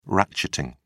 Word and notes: ratcheting: British English

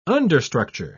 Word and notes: understructure: British English

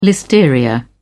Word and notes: listeria: British English

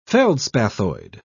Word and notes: feldspathoid: British English

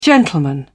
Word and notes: gentleman: British English

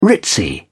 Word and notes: ritzy: British English

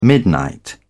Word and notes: midnight: British English

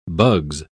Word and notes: bugs: British English

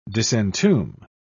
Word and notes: disentomb: British English

